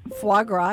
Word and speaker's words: foie gras: So that Mediterranean experience, it was Urfa dusted big eye tuna, the Greek, whipped chickpea hummus with pita, seared foie gras. Am I pronouncing that correctly? – Foie gras, yes.